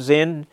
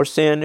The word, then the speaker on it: Zin: Then all the congregation of the children of Israel set out on their journey from the wilderness of Zin, or Sin, if you want to pronounce it that way, according to the commandment of the Lord, and camped at Rephidim.